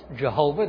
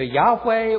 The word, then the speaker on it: Yahweh: But actually, Jehovah is probably not the best pronunciation. Because if you look at the tetragrammaton, those Hebrew consonants, you see it's probably pronounced either Yahweh or Yahweh or Yahweh, something like that.